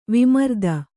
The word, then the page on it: ವಿಮರ್ದ: vimarda